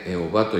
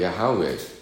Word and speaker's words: ヤハウェ: エホバという翻訳されてますが、正確な読み方ではないんですよね。エホバというのは間違った読み方でありまして、正確にこれヤハウェという発音なんですけど、発音の問題ですけどね、ヤハウェ我が牧者なりというのが本来なんでしょうね。